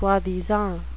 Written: soi-disant

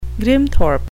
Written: grimthorpe

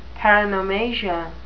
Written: paronomasia

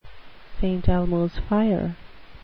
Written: st elmos fire

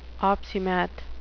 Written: opsimath

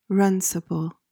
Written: runcible

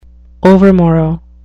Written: overmorrow

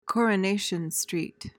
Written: coronation street

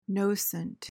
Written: nocent

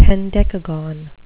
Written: hendecagon

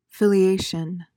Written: filiation

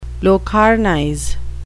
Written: locarnize

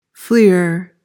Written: fleer

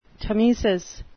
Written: tmesis

